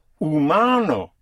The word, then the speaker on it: humano: El ser humano would be what kind of being? The human being. Humano, H-U-M-A-N-O. Yes, the H is not pronounced.